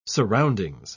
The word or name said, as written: surroundings